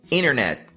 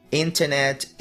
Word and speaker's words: internet: E por último, a gente tem o T, que simplesmente desaparece... quando tá junto com a letra N em palavras e frases como interview, internet, international, interrupt, advantage, percentage, 20, printout, printer, winner, inverno, que é a mesma pronúncia de winner, vencedor, enter. – Lembrando que isso vai acontecer no inglês americano.